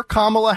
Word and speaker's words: Kamala: I'm not sure if it was for Kamala Harris or somebody at a Kamala Harris rally, but on the prompter, they phonetically spelled it out. It's C-O-M-M-A hyphen L-A. So it's Kamala.